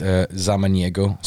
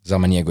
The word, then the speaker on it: Zamaniego: But my Instagram is Zamaniego Studio. Zamaniego is my last name.